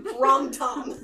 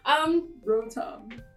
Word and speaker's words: Rotom: Rom-tom. – Rom-tom. – No, okay. – Rotom.